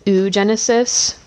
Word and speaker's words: oogenesis: oogenesis. Programming note, I know it looks like it's spelled oogenesis, it's oo, so you kind of pronounce the o's in a row instead of saying oo.